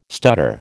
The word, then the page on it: stutter: American English